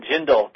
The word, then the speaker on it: Jindal: So right now they're letting Jindal talk. Is that how you say his name? – Yeah, but how you say his first name is not Bobby.